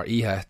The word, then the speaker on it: Iha: First guitar, I'm picking James Iha from Smashing Pumpkins, or Iha, depending on which way you want to pronounce it.